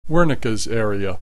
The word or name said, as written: Wernickes area